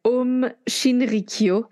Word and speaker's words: Aum Shinrikyo: Alors, il s'agit de la secte Aum Shinrikyo. Alors, il des, certaines personnes disent Aum Shinrikyo.